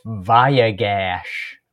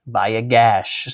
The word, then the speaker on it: Vayigash: Vayigash. – Vayigash.